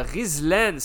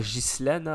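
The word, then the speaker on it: Ghizlane: Donc, en termes de meilleur euh, buteur, on a Rizlen. C'est comme ça qu'on prononce ça en arabe. Rizlen et non pas Gislen. Hein. Les Marocains ne disent pas Gislen.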